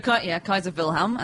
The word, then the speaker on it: Kaiser Wilhelm: So Kaiser Wilhelm, as you correctly pronounce it, Kaiser Wilhelm II was Kaiser of Germany, was King of Germany until 1918.